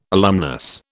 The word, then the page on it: alumnus: British English